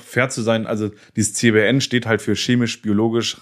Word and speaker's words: CBRN: Dein Spezialgebiet ist ja CBRN, richtig ausgesprochen? – Genau, richtig.